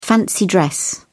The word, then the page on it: fancy dress: British English